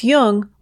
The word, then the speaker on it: Jung: Just be sure to pronounce Jung with a hard J.